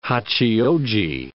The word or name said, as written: Hachioji